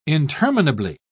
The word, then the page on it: interminably: British English